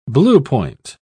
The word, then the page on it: bluepoint: British English